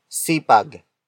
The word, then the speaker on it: sipag: Masipag is spelled as M-A-S-I-P-A-G. It means diligent or hard working. It comes from the root word sipag.